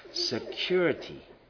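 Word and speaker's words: sécurité: There are three spoken emergency signals that show the degree of severity of the emergency, mayday, pan, and security, pronounced security.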